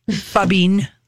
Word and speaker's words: phubbing: We haven't really, I don't know that I've completely, am completely sold on the name fubbing. – What's it? – Fubbing. – Fubbing. – Spell it. – P-H-U-B-B-I-N-G.